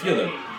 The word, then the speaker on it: Fyodor: For the longest time, I didn't know that I was pronouncing Fedor's name wrong. Theodore. It's like Theodore. – It's spelled F-E-D-O-R. – Yeah. – He's Russian. Fedor Emelianenko. It's apparently it's pronounced Fyodor.